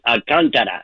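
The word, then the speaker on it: Alcántara: Alcantara. It's got an K-A-C-A has an apostrophe, a line on the top. That's an accent there. So it's Alcantara.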